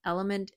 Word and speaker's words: LMNT: Element is spelled L-M-N-T.